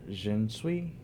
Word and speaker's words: Xinzhui: I believe it's pronounced Xinzui, X-I-N-Z-H-U-I, also known as Lady Di, a mummified woman of China's Han Dynasty, 206 BC to 220 AD.